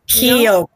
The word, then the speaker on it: Keokuk: It's Keokuk. – That is totally nothing. – Keokuk, but it's been pronounced Keokuk or Keokuk, however. Everybody pronounces it differently, but it is Keokuk.